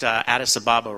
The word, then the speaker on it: Addis Ababa: By the way, Eli, yes, he pronounced Addis Ababa right.